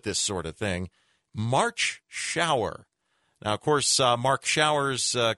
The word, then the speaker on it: Schauer: March spelled like the month, Schauer like you're taking one.